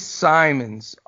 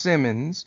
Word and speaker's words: Simons: Anfernee Simons, often mispronounced Simmons.